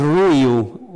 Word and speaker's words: Ruiu: Em, tengo del otro lado a la abogada, la doctora Jimena Ruiu, así creo que lo dije bien, es Ruiu, o cómo es, ¿cómo estás? – ¿Cómo estás? – ¿Cómo lo dije? Lo dije mal, ¿no? – No, no, no está bien.